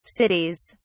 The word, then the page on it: cities: British English